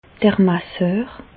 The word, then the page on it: d'r Masseur: Bas Rhin; Reichshoffen